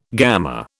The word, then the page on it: gamma: British English